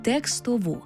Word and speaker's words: текстову: Наполегливо рекомендую опрацювати текстову. – Так, пам'ятаймо і про правильний наголос – текстову.